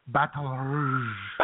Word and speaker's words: Baton Rouge: You're not pronouncing the name of the city correctly, though, J-Man. – It's Baton Rouge.